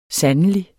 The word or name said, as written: sandelig